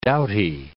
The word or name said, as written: doughty